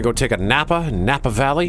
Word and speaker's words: Napa Valley: The Intruder is about a young married couple who buys their dream home in Napa Valley. Did I say that right? Napa Valley? – Napa Valley.